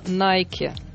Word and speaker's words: Nike: Но дело в том, что правильно эта фирма называется «Nike». Яна объяснит, почему так получилось, но, опять же, как нам известно, неправильное произношение Nike укрепилось в России.